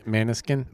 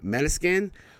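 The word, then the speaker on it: Måneskin: Maneskin. – Maneskin. Maneskin.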